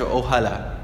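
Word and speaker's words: Ojalá: 那么有趣的是就是从历史的角度来说这个词组 Inshallah 对西班牙语都产生了影响，因为穆斯林在中世纪的时候统治过西班牙，所以在西班牙语里有这样一个短语叫做 Oyala，应该是也说错了发音应该是 Ohala，叫 Ohala 或者说 Ohala Qui，后面再加上动词，那这里的这个 Ohala 就是一个 Inshallah 的西班牙话的一个变种，就是来自于 Inshallah 这个词组，所以西班牙人，我有过一个西班牙的室友，他在说未来的事情的时候未来的计划的时候他都会说 Ohala，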